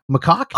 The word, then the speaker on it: macaque: It's a macaw. Macaque? – Oh, macaque? – Yeah. How the hell do you? What do you pronounce that? It's a macaque, right? – Yeah. Sure. That sounds okay.